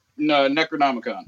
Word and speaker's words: Necronomicon: I want to ask you, what was the purpose initially that night of using the Necronomicon? How do you say it? – Necronomicon.